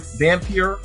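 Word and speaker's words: Vampyr: They've also published a game called Vampire, that's how you pronounce it, the vampire game, which we actually had a solid review for us last year.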